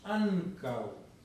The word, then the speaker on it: ankaŭ: O sea, la pronunciación en Esperanto, el acento se expresa en la penúltima vocal. La palabra ankaŭ, ¿cuántas vocales tiene? Tiene dos vocales, porque la final es uo, que no es vocal.